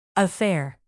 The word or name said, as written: affair